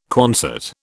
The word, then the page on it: Quonset: British English